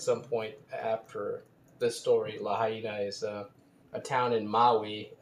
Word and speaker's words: Lahaina: Lahaina. And I know that people say Lahaina, but as we tried to mention before, I believe the correct pronunciation is Lahaina.